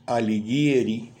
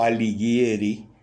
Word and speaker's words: Alighieri: Dante Alighieri o Alighieri, se puede pronunciar de dos modos,